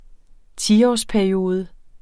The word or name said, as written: tiårsperiode